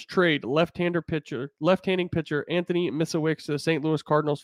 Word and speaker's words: Misiewicz: So it's Anthony Misiewicz. That's the best way I, have heard it pronounced.